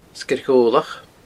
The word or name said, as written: Sgeir Ghobhlach